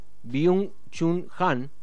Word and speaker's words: Byung-Chul Han: A esa pregunta intenta responder el filósofo surcoreano Byung-Chul Han, si es que lo pronuncié bien. – Lo pronunciaste bien.